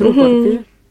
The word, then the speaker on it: рупор: Ну, рупор, правильно говорить, рупор.